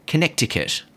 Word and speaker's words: Connecticut: And he also tried his hand at stand-up, appearing several times in the worst US state, Connecticut, because it's a silent C that's just unnecessary, in my opinion, Connecticut.